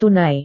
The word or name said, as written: tunai